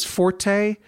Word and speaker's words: forte: Fort or versus forte. Fort as an English pronunciation of forte, meaning strong in Latin languages, or frezzo in Italian. Fort as an English pronunciation is long standing in our language.